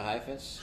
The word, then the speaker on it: Heifetz: Heifetz? – Yasha Heifetz? – Heifetz, yes.